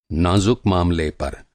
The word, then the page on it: नाज़ुक: نازُک